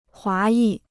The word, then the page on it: 华裔: huá yì